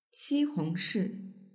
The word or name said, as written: xihongshi